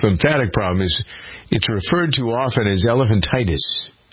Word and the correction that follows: elephantiasis: Elephantiasis, I got to pronounce that correctly.